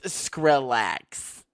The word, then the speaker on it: Skrillex: Why do you say it like that? – Skrillex. – Skrillex. – Skrillex. – It's Skrillex. – Skrillex. – You sound like, I don't know, like you can't just say Skrillex. – Skrillex. – Skrillex. – Skrillex. – It's pronounced Skrillex, okay? – Hey, fucking Skrillex over there.